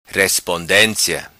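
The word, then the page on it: Respondencia: patua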